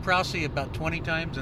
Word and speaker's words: Prause: So yes, it is Prousey. P-R-A-U-S-E, but pronounced Prousey.